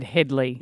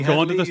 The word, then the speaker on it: Hadley: Now, I've listened back to the tape and as far as I'm concerned, you pronounce my name absolutely correctly. Tony Hadley, you might have said Hadley.